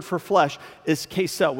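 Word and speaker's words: Kesel (כסל): You see, the, the Hebrew language has this word, and it's called kesel. It's K E C E L. It's pronounced Kesel, and it's translated lots of different ways throughout the Old Testament.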